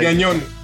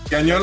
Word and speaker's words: Gagnon: No, like, Gagnon. Gagnon is perfect.